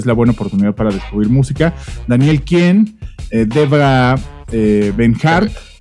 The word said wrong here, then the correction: Devendra Banhart: De Van Hart. – Van Hart. ¿Tú, tú, de Devendra Van Hart.